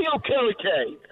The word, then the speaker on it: Okereke: Uh, he has the same first name as me, uh, Bobby Okarika, uh, Riki, or how do you say his uh, name? Bobby Okereke.